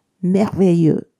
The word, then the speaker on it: merveilleux: え男性系の方が Merveilleux 女性系は Merveilleuse です。